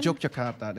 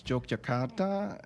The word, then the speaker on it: Yogyakarta: Yogyakarta. That was Yogyakarta.